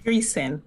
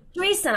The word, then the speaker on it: Dreisen: Dreisen. – Dreisen, I apologize.